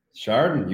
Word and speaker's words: Chardon: Chardon, you got it.